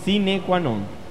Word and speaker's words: sine qua non: No es así que se pronuncia. Sine qua non.